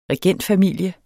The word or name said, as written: regentfamilie